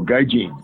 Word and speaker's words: Gaijin: I know it's G-I-G-A-N-G-I-N, the top American or foreigner. – Oh, Gaijin. – Gaijin. Yeah, Gaijin.